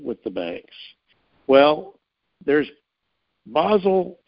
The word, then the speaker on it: Basel: Now, there are several levels of Basel compliance based on the Basel protocols, B-A-S-E-L, Basel, Switzerland, okay? And that's how it's pronounced in German. Basel.